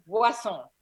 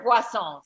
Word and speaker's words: Boisson: It's Boisson. – Boisson, like poisson. – Like croissant. – Like rhymes with fish, right? – Barrett Boisson.